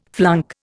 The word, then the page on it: flunk: Indian